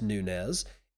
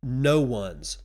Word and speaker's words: Nunez: And I was also wrong about the pronunciation of Nunez. It is not pronounced Nunez, it is no one's, like no one's chain, no one's problem.